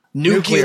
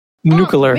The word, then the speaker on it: nuclear: Nucula. – Its skin is not based on what? – Nucula. Nuclear Sorry. Weapons. – I did pronounce that incorrectly. – True. – Nuclear.